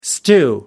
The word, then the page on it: stew: American English